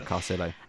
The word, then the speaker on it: Carcillo: The most recent episode, and I please forgive me if I say this wrong, is it Daniel Carcillo? Is that how you say his surname? – Yeah, Carcillo. Yeah.